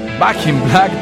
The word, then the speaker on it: Back in Black: Álbum 1980 en el Black in Black. Back in Black, perdón que lo he dicho mal.